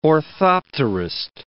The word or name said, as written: orthopterist